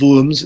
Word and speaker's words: Worms: And this is called the Concordat of Worms, or Worms, it's written, but Worms, it's pronounced.